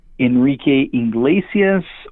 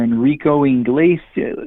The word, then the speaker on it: Enrique Iglesias: And congratulations to you for saying his name correctly, because I would say there's about 40% of the people that I talk to, not his fans. Fans know his name, but 40% of people that don't necessarily listen to Enrique don't really know Enrique Iglesias or Enrico Iglesias.